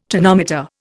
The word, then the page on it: tonometer: Indian